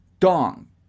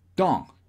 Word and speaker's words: tongue: Y ojo, no se dice tongue. Tongue. No, no, no. Tongue. Tongue. Tongue. Como que el g de tongue. Apenas si suena muy leve.